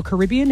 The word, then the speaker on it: Caribbean: Captain Jack Sparrow is Johnny Depp's character in Pirates of the Caribbean or Caribbean, if you say it like that.